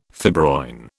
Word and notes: fibroin: British English

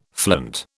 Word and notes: flint: British English

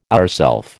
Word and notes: ourself: American English

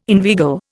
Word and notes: inveigle: Indian

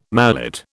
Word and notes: mallet: British English

